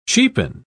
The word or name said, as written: cheapen